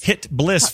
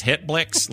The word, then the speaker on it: HitBliss: HitBliss, folks. This some people are like, how's that pronounced? HitBlix. Like, they don't know how to say it. It's HitBliss, like blissful, like bliss.